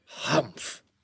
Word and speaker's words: humph: "Humph," said the sand fairy. "If you read this story aloud, please pronounce humph exactly as it is spelt, for that is how he said it."